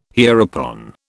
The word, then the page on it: hereupon: British English